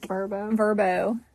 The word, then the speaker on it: Vrbo: Or VRBO. – Yeah. – So, or Vrbo, I guess that's how it's pronounced now.